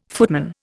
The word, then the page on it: footman: Indian